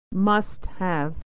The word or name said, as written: must have